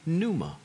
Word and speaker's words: pneuma: This one is phonetically not alliteration, but it is at least grammatically, in that it's one of those silent P's. And pneuma means this. It's the Greek word for spirit.